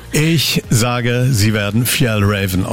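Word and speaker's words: Fjällräven: Und es wird richtig ausgesprochen, Fjell Raven.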